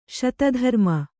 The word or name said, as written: ಸತೀಧರ್ಮ